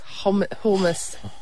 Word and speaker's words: hummus: It's not a hummus, it's hummus. You're just going to emphasise the H. – See? – Hummus. – But you're saying hummus.